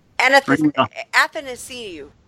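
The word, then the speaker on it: Athanasiou: So, you know, like, any bonuses that Larkin hits or Athanasiu, did I say that right? – Athanasiu. – I was close.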